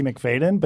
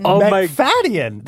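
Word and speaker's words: Macfadyen: I used to say Matthew McFadden, but that is wrong. Oh, yes. That was confusing. McFadden. – McFadden. There's an extra syllable in his last name.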